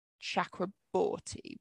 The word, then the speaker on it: Chakraborty: Chakraborty, which I think is Ch and not Sh, but I apologize if I've pronounced that wrong.